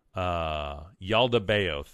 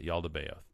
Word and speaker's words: Yaldabaoth: And if you look at that, I've been reading the Secret Book of John, which talks about the kind of false god, Yaldabaoth, I believe is how you say it. Yaldabaoth, that's how you say it. Yaldabaoth.